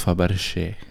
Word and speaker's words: Fabergé: Nei, det er, og, og, det uttales Fabergé. – Ja, men det er liksom ikke Det er F-A-B-R-G Apotastrof E?